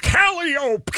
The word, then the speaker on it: Calliope: It's Calliope, kid!